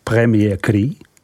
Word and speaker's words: Premier Cru: Dále následují vína Premier Cru, vyslovujeme Premier Cru.